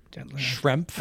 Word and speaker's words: Schrempf: How do you say his last name? Schrempf. – I think you said it just perfect. Detlef Schrempf. – Schrempf. – Not Schrempf. I think you got to pronounce that F, I think.